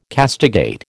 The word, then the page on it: castigate: American English